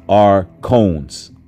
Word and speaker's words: archons: Archons, pronounced archons.